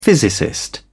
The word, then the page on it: physicist: British English